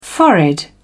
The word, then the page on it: forehead: British English